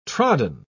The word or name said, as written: trodden